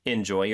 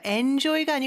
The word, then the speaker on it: enjoy: Enjoy your flight. – 빈칸에는 Enjoy, capital E-N. J-O-Y. – 네, 발음이 중요하죠. Enjoy가 아니고 enjoy. 이 음절의 강세가 mm-hmm. 있습니다.